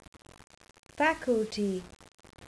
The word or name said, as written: faculty